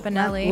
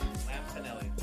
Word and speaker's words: Lampanelli: Lampanelli. – Lamp? Lampanelli. Lamp, like a lamp?